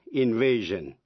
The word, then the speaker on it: invasion: Quý vị hãy nghe chữ invade, invasion, collide, collision. Những động từ đó tận cùng bằng de khi sang danh từ thì tiếp vĩ ngữ sion và đọc là jin.